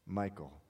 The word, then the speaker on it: Michal: Michal? How do you pronounce it? Michal.